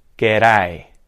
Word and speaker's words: g'day: So let's recap. The G sound, g. The reduced schwa sound, that vowel sound that's reduced, g, g, g. There's no D, it's a TD flap, okay? T or D flap. G'day, g'day, g'day.